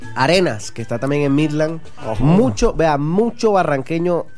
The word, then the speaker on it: Midland: ¿Esta cómo se pronuncia? Midland, así se llama. Sí. – Midland. – Sí, señor.